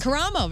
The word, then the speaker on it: Karamo: Is it Karamo? – Karamo. Very good.